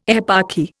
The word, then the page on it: eparchy: Indian